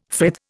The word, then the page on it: frith: Indian